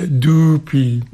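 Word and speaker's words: Doopee: I uwaga, uwaga, pisze się D-O-O-P-E-E i mówi się Dupi. – Dupi, tak. – Długie U.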